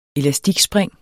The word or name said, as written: elastikspring